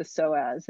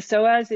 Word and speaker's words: psoas: She studies the psoas, and I think she's very interesting. – And what she talks about the psoas is your deepest abdominal muscle. It's spelled PSOAS.